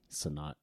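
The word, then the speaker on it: Cenat: How do you pronounce it? – Sanat. – I think it's Sanat.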